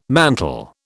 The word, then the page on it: mantle: British English